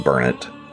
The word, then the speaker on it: Burnet: That's spelled B-U-R-N-E-T, but from my understanding, it's called Burnett. Because, you know, this is Texas, and why not say things differently than spell?